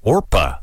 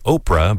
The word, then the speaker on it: Orpah: Oprah said that actually on her birth certificate, her God-given name is actually Orpah, not Oprah, but Orpah. Yeah, from the Bible story of Ruth. Relatives, though, kept mispronouncing it over the years, and Oprah just kind of stuck.